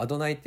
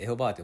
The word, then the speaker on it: アドナイ: 今は「アドナイ」って読むんですけども長らく間違えてエホバーと読まれてました。